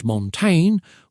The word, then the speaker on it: Montaigne: Michel de Montaigne, pronounced Montaigne, was